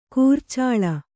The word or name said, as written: ಕುರುಚಲು